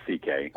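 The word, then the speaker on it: Székely: He goes by Louis C.K., and he's his last name is pronounced, it's spelled differently. It's than C.K., but it's pronounced C.K.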